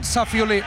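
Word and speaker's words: Safiullin: En tenis, Alcaraz Safiu, l'he pronunciat bé? – Doncs, Roman Safiuli, Roman Safiuli.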